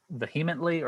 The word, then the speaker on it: vehemently: And I was, how do you say this word? Vehemently or? – Vehemently. – Vehemently. So the, I think the H is silent.